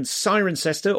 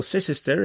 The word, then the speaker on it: Cirencester: There's another one of those in Cirencester, or Cicester, if you're from the 1800s and care about how you pronounce things.